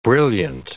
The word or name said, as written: brilliant